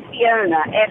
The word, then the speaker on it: Fiona: Fiona, F-I-O-N-A.